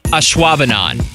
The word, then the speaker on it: Ashwaubenon: This one's pronounced Ashwaubenon, okay? Do you think that any of them, even just one of these four people from Wisconsin, were able to pronounce this Wisconsin city? Ashwaubenon.